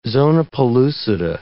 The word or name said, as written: zona pellucida